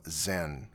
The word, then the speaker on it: Xen: Music of Arca from his album Zen. As best we know, that's how it's pronounced, but it's spelled X-E-N.